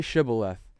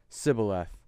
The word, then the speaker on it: Shibboleth: He says, say Shibboleth. And then he replied, Sibboleth.